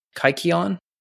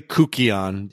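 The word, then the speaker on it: kykeon: Kaikion? – It's technically Kukion. – Oh, Kukion. – It's spelled as Kaikion.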